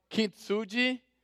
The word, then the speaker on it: Kintsugi: No, it's called Kintsugi.